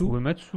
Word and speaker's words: Uematsu: Uematsu.